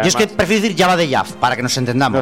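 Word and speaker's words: Jabba the Hutt: Espera un momento, has dicho Jabba the Jab. – Sí. – Pues es que lo has pronunciado un poco raro. Es Jabba the Jab,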